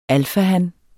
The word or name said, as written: alfahan